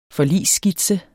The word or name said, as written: forligsskitse